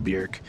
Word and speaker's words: Björk: It's pronounced Björk. – Not Björk? – Yes, I got schooled and got told how to go learn how to pronounce it if I wanted to review all her music, and I said, fair point. And it's basically like jerk, but Björk.